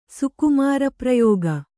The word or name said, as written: ಸುಕುಮಾರಪ್ರಯೋಗ